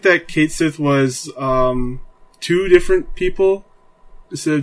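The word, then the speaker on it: Cait Sith: Kate Sith seems to be pronounced as Ket Sith, which this is a crime.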